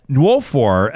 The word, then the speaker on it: Nwofor: And rounding out our panel today is the president of Black Lives Matter down in Calgary, Adora Nwofor.